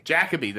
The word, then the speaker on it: Jacobi: Jacobi, that's right.